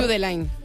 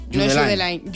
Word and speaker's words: Judeline: Pues entonces Judelín. Judelín. – Judelín. – Si es de Barbate, Judelín.